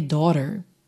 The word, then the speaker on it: daughter: Então, aqui nessa pergunta, nessa primeira linha, daughter, atenção aí para a pronúncia dessa palavra, porque quando a gente vê daughter por escrito, né, D-A-U-G-H-T-A-R, você fala, que palavra é essa? Não é difícil, daughter, daughter.